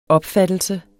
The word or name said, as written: opfattelse